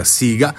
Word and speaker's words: Sega: Ma come ho detto prima, vennero fuori anche nuove piattaforme, tipo la Atari, la Namco, ricordiamo anche oppure la Sega, non fa degli spiritosi, pronuncia Sega, ma scrive S-E-G-A,